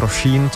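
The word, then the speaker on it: Róisín: Ona má píše se to Roisin, čte se to Roisin, což mě celkem překvapilo.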